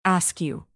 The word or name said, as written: askew